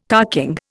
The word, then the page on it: carking: Indian